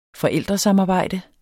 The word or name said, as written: forældresamarbejde